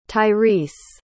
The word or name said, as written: tyreece